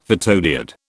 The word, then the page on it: photodiode: British English